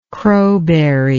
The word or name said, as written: crowberry